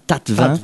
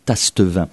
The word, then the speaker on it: Tastevin: Tu confirmes qu'on prononce bien Tatevin ? – Tatevin, oui. – Alors que si on le lit de manière très phonétique, on devrait dire Tastevin.